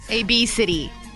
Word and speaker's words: Abcde: It's spelled A-B-C-D-E. – A-B-City. That's how you pronounce it. A-B-City. Which sounds like freaking obesity. – Wait, and how is it spelled again? – A-B-C-D-E. – A-B-C-D-E.